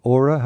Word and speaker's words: Åre: Yeah, Åre is the best skiing area in Sweden.